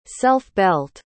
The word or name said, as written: self-belt